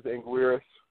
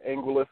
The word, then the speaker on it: Anguirus: And the first introduction of a giant monster other than Godzilla, and that of course is Anguirus, or Angulus, however you the Japanese tend to pronounce that Angulus, but if you're doing it the English way, we'll go with Anguirus.